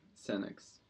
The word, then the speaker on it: Senex: А вот шестой трек, Senex. Правильно а, произношу? да, да.